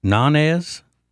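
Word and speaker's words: Ñáñez: But it wasn't until I got the real story from the Venezuelan communications minister, Freddy, I guess his name's pronounced Nanez.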